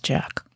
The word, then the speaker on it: JATK: J-A-T-K, he spells it. Yeah, I went back and I read his email and he told me at the very bottom it's pronounced Jack.